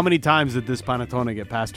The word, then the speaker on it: panettone: This one, Chef Swagger, Panettone. – Oh, nice pronunciation.